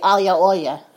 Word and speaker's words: aglio e olio: Being New Jersey Neapolitans, we of course do not pronounce it aglio e olio. We say aglio olio.